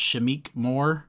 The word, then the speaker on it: Shameik Moore: He's voiced by, uh, I think it's pronounced Shameik Moore, mm-hmm.